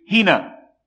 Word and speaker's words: hina: In 1 John chapter 2, my little children, I write these things unto you, and the next word is a 3-letter particle in Greek, pronounced 4 letters in English, hina, meaning in order that.